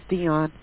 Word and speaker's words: Deon: My name's Dion. – Can you spell that for me? – D-E-O-N.